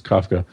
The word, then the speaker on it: Kafka: What Peter Kafka wrote, I keep mispronouncing his name, I'm sure it's Kafka.